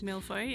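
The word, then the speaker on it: mille-feuille: There was a chocolate milfoy, is that how you pronounce it? – Milfoy, yep.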